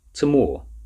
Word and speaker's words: Tamor: Tamor, said the professor, not looking up from her hand. Tamor? Tamor. Emphasis on the second syllable.